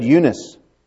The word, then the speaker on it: Eunice: Most of you would have said Eunice. No. No. You got a mark over the EU? Looks like an upside down arrow. It looks like an arrow. And the emphasis on the E or on the I. Uh-huh. And the long E.